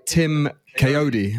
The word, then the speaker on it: Tim Kayode: We are joined by Tim Kayode.